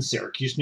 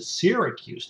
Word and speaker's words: Syracuse: And if you're from Syracuse, New York, you pronounce it Syracuse, New York, Syracuse, New York.